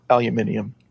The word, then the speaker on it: aluminium: So that just goes back to, it's going to be pronounced aluminium. – Okay. Cause that's how they pronounce it. – Cause that's how they do it. Yeah. – Yeah. That's fair. Plus, it is, you know, that's one of those ones that is spelled like it should be pronounced aluminium. – Yeah. – We just as Americans, we like to take that shortcut, not pronounce it all.